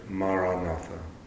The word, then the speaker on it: Maranatha: But otherwise, the word I would recommend is the word Maranatha. Four syllables Ma, Ra, Na, Tha. Tha.